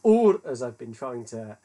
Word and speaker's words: Ur: Linguistically, it should be pronounced in Akkadian, which is the language that they spoke there. Now, hang on for this, my Akkadian's terrible. As Ur or Uru.